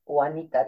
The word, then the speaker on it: Juanita: It's like Juanita is a J, but it's pronounced Y, Juanita, remember. Every time they look at my name, they say.